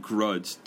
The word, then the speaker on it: grudge: Garage는 차고이고 이거는 grudge, grudge. 그리고 한국식 발음으로 하면 안 돼요. grudge이라고 하면 안 돼요. 그냥 grudge 대신에 이렇게 끝나는 끝나면 돼요. grudge.